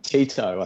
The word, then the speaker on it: Tito: Tito, I think is how you pronounce it. T-I-T-O, okay.